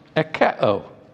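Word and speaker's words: ekeo (ἠχέω): The word here in Greek is closely related to a word that's pronounced ekeo. Ekeo. My wife hates it when I pronounce Greek words when I preach or teach. But ekeo is where we get the word echo.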